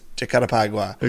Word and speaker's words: Jacarepaguá: I looked up many different pronunciations for it. I believe it's Jacarepaguá.